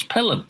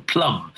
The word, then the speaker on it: Pelham: But as a child, he could never pronounce Pelham. And when he tried, he went Pelham, Pelham, Pelham, Pelham, Plum. So everyone called him Plum.